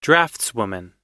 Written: draftswoman

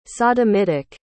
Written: sodomitic

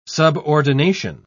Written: subordination

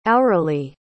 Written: auerelie

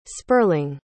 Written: spurling

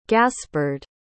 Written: gaspard

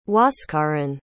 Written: huascarán

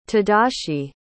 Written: tadashi

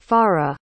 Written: fara

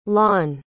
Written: lon

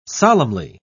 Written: solemnly

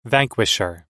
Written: vanquisher

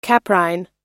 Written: caprine

